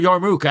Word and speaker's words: yarmulke: And so, you know, I had to go in and I put on a yarmulke. I probably not said that right. – Yarmulke? – It's called a yarmulke, actually.